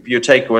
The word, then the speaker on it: Buteyko: And that's spelled B-U-T-E-Y-K-O, isn't it? – Yeah, correct. Yeah, Buteco cool.